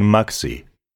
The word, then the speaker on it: Magsi: Nein, ich heiße Sachin Maxi. – Ah, dann bitte einen Moment. Schreibt man Maxi M-A-G-S-I? – Ja, das ist richtig.